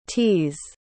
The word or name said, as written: t's